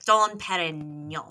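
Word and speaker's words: Dom Pérignon: I don't think you say the G. It's like Dom Perignon.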